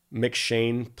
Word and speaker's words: M'Cheyne: This is my McShane reading plan. Yeah. Help me spell it. M'CHEYNE. – Y-N-E, yes. – Yeah. McShane.